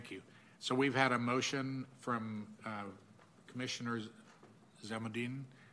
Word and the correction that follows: Zamanadine: I've mispronounced it. Zamanadine. And a second from Commissioner Korbes to accept the allocations.